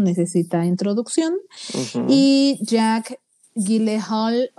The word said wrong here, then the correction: Jake Gyllenhaal: o como se pronuncie. – Jake Gyllenhaal. Jake Gyllenhaal.